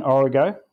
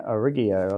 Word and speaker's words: Origo: So I have to correct your pronunciation, Origo, which is just Latin for the source. – How did I pronounce it? – Yeah, Origio or something.